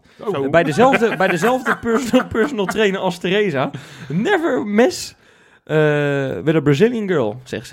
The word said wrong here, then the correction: mess: Ah, sorry, mijn uitspraak is niet helemaal geweldig, maar nee, never mess.